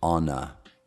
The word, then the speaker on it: honor: Now, um, I had a, a real wonderful honor. Non si pronuncia l'H in honor, anche se c'è l'H all'inizio. Sono i pochi paroli inglesi che, che c'è scritto l'H, ma non si pronuncia.